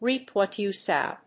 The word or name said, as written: reap what you sow.